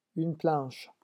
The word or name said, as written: une planche